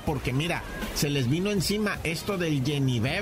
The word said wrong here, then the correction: Genevieve: ¿verdad? ¿O cómo se dice Genevieve? Genevieve, bueno, el huracán categoría 3 que les está pasando por un lado, yo creo.